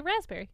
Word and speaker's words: raspberry: Also, while you're looking that up, raspberry. That P really sneaks in there without us knowing. Raspberry is how it's spelled. – Yeah. – Yeah, but we say raspberry.